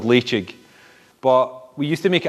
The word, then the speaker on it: Ledaig: And that's what I sense in Leitchig. You know, the fact that it's spelled L-E-D-A-I-G and it's pronounced Leitchig is one early indicator of a high-quality single malt scotch.